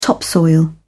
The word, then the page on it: topsoil: British English